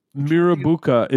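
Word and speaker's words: Mirrabooka: Mirabuka is a tough one. I'm curious about that. Mirabuka. You could have less of an emphasis on the A in the middle.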